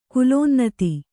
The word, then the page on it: ಕುಲೋನ್ನತಿ: kulōnnati